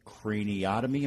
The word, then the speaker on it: craniotomy: which is the awake craniotomy.